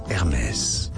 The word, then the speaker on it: Hermès: I hear too many people pronouncing this brand name from France absolutely wrong as Hermès or Hermès. No, in French it is said as Hermès. So, in English, Hermès, Hermès. – Yes, Hermès. H-E-R-M-E with a stripe and then S.